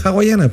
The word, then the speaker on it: hawaiana: Ah, pero que se llaman hawaiana, po. – Sí, pero le dicen la guayana. – La guayana. Es que la H no se pronuncia porque muda.